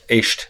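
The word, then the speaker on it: Éist: Tell us about Aist and that whole, is that the right pronunciation? – Aist. – Aist. – Yeah.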